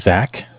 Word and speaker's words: Zach: Yeah, my name is Zach, spelled Z-A-C-H.